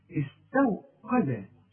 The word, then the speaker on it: استوقد: شوف استو غلط عارف ليه تنطقها زي ايه زي استوى شوف استوى استو قا ما انا بقول لك ادي كل حرف حقه في القران القاف قا انما الواو اي مرققه والتاء احنا قلنا اتفقنا انها مرققه استوقد شوف واذا وقفت استوقد والدرج دي من حروف القلقلة هناخدها إن شاء الله.